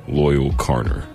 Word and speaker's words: Loyle Carner: But let's start off with the hardest name to pronounce in this whole mix, Loyle Carner. – Because it's not, if you guys haven't seen the way his name's spelled, it's not loyal like, I'm a loyal boyfriend. No. It's L-O-Y-L-E.